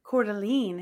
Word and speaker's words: Coeur d'Alene: I am taking us to, and I hope I'm saying this right, Coeur d'Alene. – Oh, Coeur d'Alene.